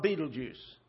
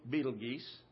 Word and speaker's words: Betelgeuse: Well, how about Betelgeuse? Or if you want the Latin pronunciation, Betelgeuse.